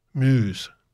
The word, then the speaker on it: Muse: Musa, whatever they pronounce it. Muse.